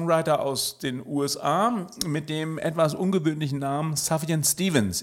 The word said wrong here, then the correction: Sufjan: Ich hoffe, das Savian spricht man richtig aus. Suvian Stevens.